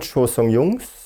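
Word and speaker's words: Cho Sung-hyung: Cho Sung-Jungs. Ich hab sie richtig ausgesprochen.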